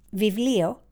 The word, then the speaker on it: βιβλίο: Vivlio in Greek means book. The letter B is pronounced V.